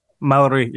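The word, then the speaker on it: Māori: Waititi, who is Maori? Am I saying that correctly? Maori? – Maori, yeah.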